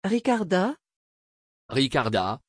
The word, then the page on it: Ricarda: French